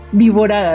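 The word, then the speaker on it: Wiborada: canoniza a Wilborada, Viborada realmente. Se escribe igual pero sin la L.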